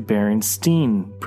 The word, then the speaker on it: Berenstain: Despite the A being in the title, the show opts to go with the Berenstain pronunciation.